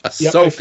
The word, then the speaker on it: Ahsoka: I was doing the bit where it was Oshkosh by gosh, but people weren't taking that seriously. So I learned how to say it to Ahsoka. – And I was saying it like Asuka, the World Wrestling Entertainment wrestler.